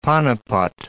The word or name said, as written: Panipat